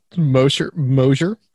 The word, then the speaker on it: Mosher: And Heather Mosher. – Hello. – Did I say that right, Heather? – I say Mosher. More like a Z, but okay. – Yeah. – Okay. All right. Mosher.